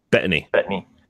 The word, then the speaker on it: Bettany: I know that Paul, is it, how do you pronounce his last name? Bettany.